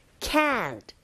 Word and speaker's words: can't: 그래서 강세를 받기 때문에 우리가 can't의 t 사운드가 잘 안 들린다 하더라도 can't, can't 이렇게 길게 발음하게 되죠.